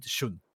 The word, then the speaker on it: Shun: I might have said Sean, but that's because I keep forgetting the pronunciation in English for the name. It's I might have said it like that, but I meant Shun.